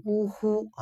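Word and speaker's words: 於戲: 呜呼呜呼这两个字呢，在这儿呢，它写作叫鱼戏，鱼戏呢，但它要发音要读呢，要读呜呼啊，就是呜呼。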